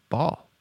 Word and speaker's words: Ball: I'm like, ball, like a basketball, like B-A-L-L.